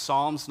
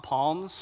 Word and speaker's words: Psalms: It's pronounced Psalms, not palms, for anyone who's new to the Bible. I remember when I became a Christian, I thought it was palms, but silent P there.